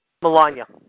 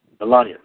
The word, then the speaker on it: Melania: Melania. – Melania, thank you.